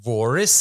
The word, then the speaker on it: Voris: Jeg jeg hørte en podkast med Kurt Forris Altså, Voris sier de i USA, det skrives jo med V. Det er helt sikkert et tysk navn som skulle uttales med F, men det er jo sånn er vel amerikanere.